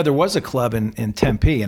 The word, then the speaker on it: Tempe: No, you said Tempe.